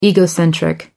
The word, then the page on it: egocentric: American English